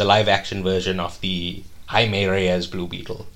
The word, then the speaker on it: Jaime: It's spelled like Jamie. – Yes. – But it's pronounced Jaime.